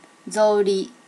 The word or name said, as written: zori